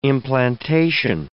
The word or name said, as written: implantation